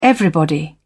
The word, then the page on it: everybody: British English